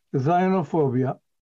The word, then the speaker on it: Zionophobia: Therefore, I use the word Zionophobia, which rhymes with Islamophobia.